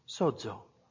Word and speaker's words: sozo: The Greek word in Strong's for saved is 4982, pronounced sozo, which means to heal or to make whole.